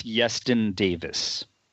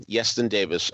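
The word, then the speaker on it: Iestyn Davies: It's Yeston Davis. – All right, so the I is pronounced as a Y, huh? Okay, fine. Yeston Davis, a countertenor who does a phenomenal job of the singing.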